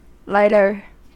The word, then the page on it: lighter: American English